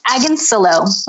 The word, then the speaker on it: Agoncillo: It's Agoncillo. Rhymes with pillow.